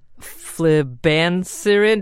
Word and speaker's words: flibanserin: The drug, pharmaname flibanserin. Did I say that right, Carrie? That was it. Okay. – Yep.